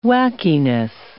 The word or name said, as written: wackiness